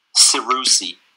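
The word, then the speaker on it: Serusi: Cerusi. – Cerusi. – S-E-R. Yes, kind of easy to say and harder to read.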